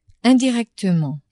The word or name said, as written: indirectement